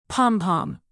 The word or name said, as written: pompom